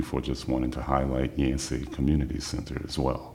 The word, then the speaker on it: Yancey: The director of the Yancey School Community Center.